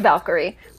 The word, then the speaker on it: Valkyrie: So it is Valkyrie. – Yes, Valkyrie. Which, okay. – Like. – Okay, I was wondering. That's why I, like, double-checked last time, and you said it as Valkyrie again, and I was like, oh, okay, it is Valkyrie, got it, great.